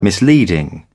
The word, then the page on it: misleading: British English